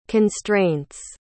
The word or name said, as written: constraints